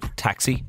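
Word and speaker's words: tacsaí: We have taxi, T-A-C-S-A-I, father, but taxi is pronounced the same.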